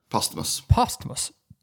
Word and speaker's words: posthumous: How do you pronounce that word? – Posthumous.